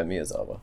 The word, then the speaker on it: Miyazawa: Yeah, Miyazawa.